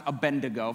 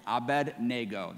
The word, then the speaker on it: Abednego: Now again, it's not pronounced Abednego, for some of you getting irritated with me right now. It's Abednego, that's the way to pronounce it.